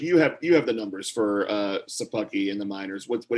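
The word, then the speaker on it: Szapucki: They called up my man Shapuki. – Yeah. You know, actually, if I'm not mistaken, I think it's pronounced Sapucky. I was listening to the Mets broadcast and Gary Cohen called him Sapucky.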